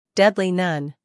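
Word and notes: deadly: uk /ˈdedli/; en-us /ˈdedli/

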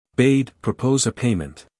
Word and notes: bade: beɪd

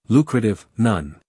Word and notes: lucrative: ˈluːkrətɪv

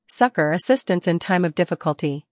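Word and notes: succour: uk ˈsʌkə(r); en-us ˈsʌkər

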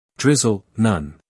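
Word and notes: drizzle: uk ˈdrɪz(ə)l; en-us ˈdrɪz(ə)l

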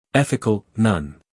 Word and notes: ethical: ˈeθɪk(ə)l

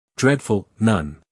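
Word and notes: dreadful: uk ˈdredf(ə)l; en-us ˈdredf(ə)l

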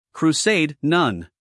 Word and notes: crusade: kruːˈseɪd

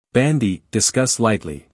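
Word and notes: bandy: uk ˈbændi; en-us ˈbændi

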